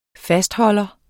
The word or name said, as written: fastholder